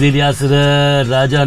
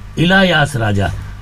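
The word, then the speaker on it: Ilaiyaraaja: इलायास या प्रोनाउंसिएशन के लिए, क्योंकि साउथ के हैं वो संगीतकार, कहते हैं it is इलायास राजा, not इलियास राजा।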